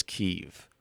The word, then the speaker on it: Kyiv: I'm just saying the Ukraine and the Ukrainian president and the Ukrainian people refer to it as Kyiv. Because I agree with you, because when I was watching the news broadcast, I noticed all the guys on NBC and so on pronounced it Kyiv, and I'm like, well, what's this about?